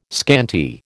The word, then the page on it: scanty: American English